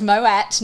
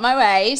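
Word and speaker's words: Moët: It is Moet, not Moet.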